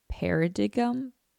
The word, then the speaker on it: paradigm: This makes it nearly impossible to think outside the standard paradigm. The standard way of life. P-A-R-A-D-I-G-M. How do you say that word? Paradigm. If you just say it fast, it makes sense.